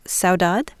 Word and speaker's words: Saudade: Saudade? – Yes. – Am I pronouncing that right? – Yes.